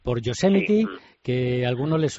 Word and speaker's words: Yosemite: Yosemite se pronuncia aquí. Vale, Yosemite.